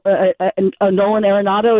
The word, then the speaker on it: Arenado: The one reason I would have preferred Nolan Arenado, is that how his name is pronounced? – Yep.